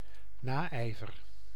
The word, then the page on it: Neid: /naɪ̯t/